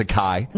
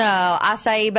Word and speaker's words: acai: Yeah, I thought it was a kai. – No, acai, but I had to do the little,